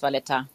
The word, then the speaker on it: Valletta: Hauptstadt äh, Valletta, wird das, ist das mhm. richtig ausgesprochen? Richtig, genau, unsere Hauptstadt ist Valletta.